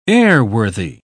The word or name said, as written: airworthy